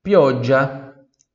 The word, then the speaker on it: pioggia: Se escreve G-I-A-L-L-O. G-I-A-L-L-O. Giallo, giallo. Pioggia. Pioggia, você já percebeu que se tem um G ou dois Gs, não muda a pronúncia, praticamente não, não muda a questão do, do D, ela vai estar sempre presente ali, tá? Pioggia, pioggia, budia, pioggia, ele é um, é um pouco mais longo só, né?